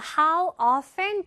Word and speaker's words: often: 자, how often do you play? 라고 들으셨나요? 우리 쓸 때는 how often, 트 발음 잘 안 들리고요.